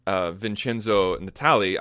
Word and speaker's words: Vincenzo Natali: Now, one thing that Fuller said was that they haven't booked any directors yet, but he did say that they have booked Vincenzo Natali. I know I'm probably saying that name wrong. – No, that's right, which is interesting, because I think of him mainly as a horror director, which fits in with Brian Fuller, I guess.